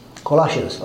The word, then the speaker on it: Colossians: Or in Colossians, he says, how do you say in English? Colossians.